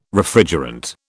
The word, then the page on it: Refrigerant: British English